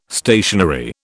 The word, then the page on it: stationary: British English